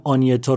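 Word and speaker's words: Agnetha: آنیتا رو باید اگنتا بنویسن اما آنیتا بخونن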